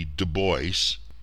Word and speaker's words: Du Bois: W.E.B. Du Bois, pronounced usually Du Bois, regarding the American church, no other institution in America is built so thoroughly or more absolutely on the color line.